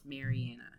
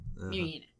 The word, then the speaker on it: Marianna: Because it's short A, so it's Marianna. Marianna.